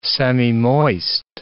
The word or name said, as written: semimoist